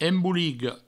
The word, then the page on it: emboliga: embouligo